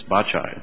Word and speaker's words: Baccei: It's the BACCEI case, Bacci, I'll use the Italian pronunciation.